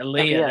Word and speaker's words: Aaliyah: For the minute and 5 seconds that she was in the match for, number five, is it Aaliyah? I don't know how to pronounce her name. Aaliyah. There we go.